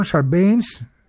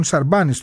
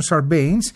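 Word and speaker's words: Sarbanes: ο Τζον Σαρμπέινς, Τζον Σαρμπάνης το λέμε εμείς, αλλά στην Αμερική έτσι με την προφορά το κάνουν Σαρμπέινς, ο Κρις Πάπας και η Ντίνα Τίτους.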